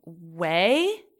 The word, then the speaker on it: Ouai: Right now I'm using, Oribe, Way. I think that's how you say it. It's like O-U-A-I.